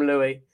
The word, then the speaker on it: Louis: It's like all you Brits call yourselves Lewis and spell it Louis. So we just all automatically say Lewis. But no, I'm properly, I'm Louis.